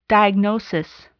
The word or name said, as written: diagnosis